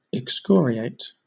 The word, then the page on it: excoriate: British English